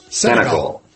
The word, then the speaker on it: Senegal: Senegal? – Senegal.